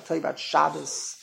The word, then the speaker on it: Shabbos: So when you say Shabbos, you're talking Yiddish. In Yiddish, there's no such thing as putting an emphasis on the last syllable of the word. In Yiddish, the rules of Yiddish are every single word, the emphasis is on the beginning of the word. So you say Shabbos, that's correct.